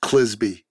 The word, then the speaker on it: Clizbe: There's a book you ought to check out called Willing Accomplices, written by a former CIA case officer named Kent Clisby, rhymes with Frisbee, C-L-I-Z-B-E.